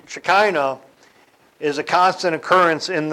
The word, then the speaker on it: Shekinah: The title of this is Shekinah. Let me spell that for you. S-H-E-K-I-N-A-H. Shekinah. It also can be spelled with a C-H instead of the K, but it has the same sound.